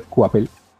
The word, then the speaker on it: Coipel: Sí, que también, que eso lo dibujó Olivier Coapel, como, como por ahí alguna vez me ha corregido Jorge Tobalín, que así se pronuncia, Oliver Coapel. Yo decía Oliver Coipel, pero pues así es su apellido.